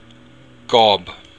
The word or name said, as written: gob